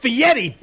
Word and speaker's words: Fieri: It's pronounced Fieri, bitch.